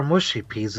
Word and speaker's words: mushy peas: Saying it wrong again. For some reason, nobody over this side of the water can say it properly. Mushy peas, not mushy. We know who mushy is. That's Michelle.